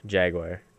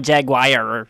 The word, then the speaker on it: jaguar: Jaguar. Not jaguire. – Jaguar. Jaguar. – There is no I sound in there.